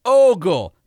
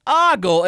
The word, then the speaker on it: ogle: is correctly spelled with only one O and is correctly pronounced with a long O, as in ogle, not ogle, and absolutely, positively, never ever oogle like Google without the G.